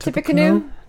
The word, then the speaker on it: Tippecanoe: Tippecanoe. Is that how you say it? – Tippecanoe, also known as a Tecumseh's curse.